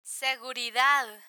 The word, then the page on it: seguridad: spanish